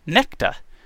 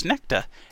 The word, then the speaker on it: Nexta: Nexta. Pronounced Nekta. I'll put that. Pronounced Nekta.